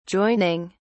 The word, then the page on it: joining: British English